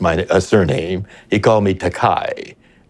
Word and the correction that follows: Takei: but I corrected that. I said, my name is pronounced Takei, but I don't object to Kai because that's a Japanese word that means expensive.